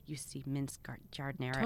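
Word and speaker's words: giardiniera: I have a I'm going to probably pronounce it wrong. Jardinera? – Yeah, Jardinera. – Okay, I always say Gardinera.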